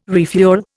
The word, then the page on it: refuel: Indian